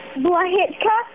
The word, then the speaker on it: Blahitka: Nikki Blahitka. – Say that last name one more time. – Blahitka. – Can you spell that for me, please? – B-L-A-H-I-T-K-A.